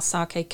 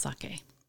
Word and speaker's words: sake: Well, first off, how do you pronounce sake? – Okay, I think you girls were doing really, really well there. So sake, so kind of like okay, so sake rather than sake.